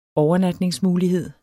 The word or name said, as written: overnatningsmulighed